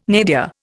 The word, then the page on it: nadir: Indian